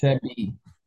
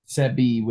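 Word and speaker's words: Seby: I think it's Sebi Zabala or Sebi. It's Sebi. – Sebi. – Sebi with a B.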